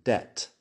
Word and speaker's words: debt: Como si no estuviera ahí. Doubt, debt. Tienes la D, tienes el sonido de la vocal y tienes la T la B se escribe pero no se pronuncia.